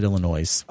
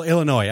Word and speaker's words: Illinois: The S is silent, by the way. You said Illinois. – Oh, Illinois. I'm sorry.